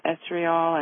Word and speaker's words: estriol: Is it estriol and estradiol? Or how do you say estriol? How do you say that? – Estriol, that's exactly how you say it.